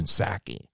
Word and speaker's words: Psaki: You know, the P is actually silent, so it's Jen Psaki.